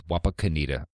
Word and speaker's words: Wapakoneta: And I looked up the pronunciation. It is Wapakoneta.